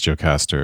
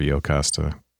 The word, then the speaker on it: Jocasta: Because Aaron, you've always heard it Iocasta. I've always heard it Jocasta. – Right. – And your pronunciation is what it is in the actual ancient Greek. And I guess the way I've heard it is the anglicization.